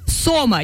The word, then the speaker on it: сома: Правильно говорить сома. Я узнала это буквально 5 минут назад.